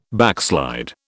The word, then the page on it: backslide: British English